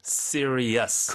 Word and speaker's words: serious: 아, serious. – 그건 또 serious가 되는군요. 네. – 끝에 e-us 발음이죠.